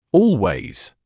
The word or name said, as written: always